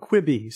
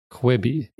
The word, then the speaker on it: Quibi: This is called Quibi. So Quibi.